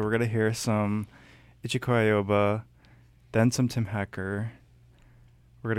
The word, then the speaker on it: Ichiko Aoba: Ichiko Aoba? – I think that's, yeah.